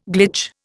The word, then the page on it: glitch: Indian